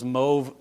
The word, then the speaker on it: mauve: Those mauve places there, yes, that is how the British pronounce the word.